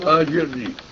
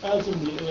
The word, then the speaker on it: أجرني: الهمزة فوق سكون لو أردنا أن نبتدئ بها كيف تلفظ؟ كلمة أجرني لوحدها. – أجرني. – أجرني يعني تحول السكون. – إي نعم. – أجرني. – لأن السكون لا يبتدا به. – هذا هو نعم.